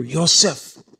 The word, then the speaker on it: Yosef: Speaking as you would pronounce his name in Hebrew. Yosef.